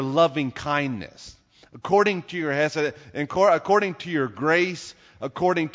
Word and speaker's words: chesed: In the Old Testament, the word in the Hebrew is hesed, it's spelled C-H-E-S-E-D, hesed.